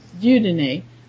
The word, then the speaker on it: Dieudonné: how do you pronounce his name? – Dieudonné.